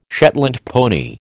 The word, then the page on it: Shetland pony: American English